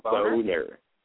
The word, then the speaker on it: Boehner: Congressman Boehner. It's B-O-E-H-N-E-R, Boehner.